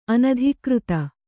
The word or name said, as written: ಅನಧಿಕೃತ